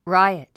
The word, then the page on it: riot: ráiət; ライアト